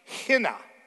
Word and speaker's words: hina: It's the Greek word, whenever you see that, I-V-A, it's actually not I-V-A, it's iota, nu, alpha, okay? It's pronounced hina.